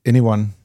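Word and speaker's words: Ny1: Du sagde, den udtales nok Anyone. – Ja, plus E'et foran.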